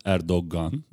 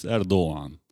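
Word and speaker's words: Erdoğan: It's not Erdogan, as many people say. It's Erdogan. – Erdogan. – It's G, but it's soft G.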